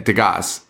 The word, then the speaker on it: Degas: And they're like, yes, sir, Mr. Degas. No, Degas. – Degas. – Yeah, Degas. And he's like, it's Degas. The S is silent.